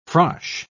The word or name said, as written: frosh